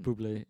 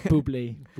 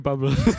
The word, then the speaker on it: Bublé: Bublé, ja. – Ja, sådan noget den stil. Bublé, jeg kan ikke udtale yes. – hans. Not trusting. Ja, præcis. Yeah. Den sang. Bublé. Bublé. Ikke bubble.